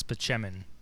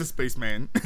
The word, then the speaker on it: Spaceman: It's pronounced Spaceman? I thought it was Spacheman. – Oh. No, it's Dr. Spaceman.